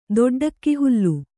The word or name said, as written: ದೊಡ್ಡಕ್ಕಿಹುಲ್ಲು